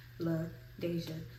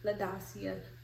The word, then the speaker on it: La Deja: So I know this is my first time saying this, but my actual name is La Deja. Okay? But people will always say La Dacia, La Deja, or like they just would say it wrong.